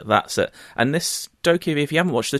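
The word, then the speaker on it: Dokev: I think it's pronounced Dokevi. It's Dokevi. It just looks mad.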